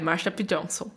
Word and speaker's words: Marsha P. Johnson: Quem prestou atenção no episódio da, da Marsha P. Johnson? Eu falei certo? – É, Marsha P. Johnson.